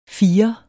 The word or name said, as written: firer